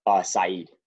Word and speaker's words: Saeed: Now, your older brother, is it Said? Am I correct? – Saeed. Yeah. – Spelled like Said, but yeah, Saeed, yeah.